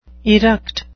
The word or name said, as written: eruct